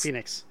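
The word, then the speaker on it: Fénix: It's spelled Phoenix. – F E N I X. It's Phoenix.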